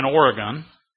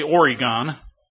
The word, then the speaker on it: Oregon: And he started out up in Oregon. I used to pronounce it Oregon because Louis Van Alstel would flinch. He would say, "You did not say Oregon." And so the next time I'd say Oregon.